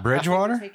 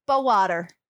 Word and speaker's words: Bowater: Bridgewater? – No, he says B-O-W. Bowater.